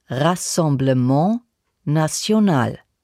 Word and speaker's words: Rassemblement National: Rassemblement National ist französisch. Das spricht man ungefähr so. Rassemblement National.